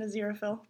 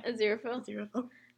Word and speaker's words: Aziraphale: Now we're gonna bring down Crowley and Aziraphale. – Oh my god. Is that how you pronounce his name? – Yeah. – Aziraphale.